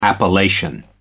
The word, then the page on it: appellation: American English